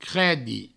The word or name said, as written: credi